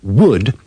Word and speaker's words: would: In these dialogues, remember to pronounce would and should with a schwa.